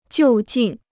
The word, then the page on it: 就近: jiù jìn